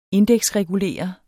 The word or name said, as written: indeksregulere